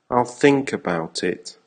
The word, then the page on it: I'll think about it: American English